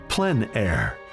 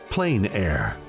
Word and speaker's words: plein air: The French pronounce it plein air. Others say plain air.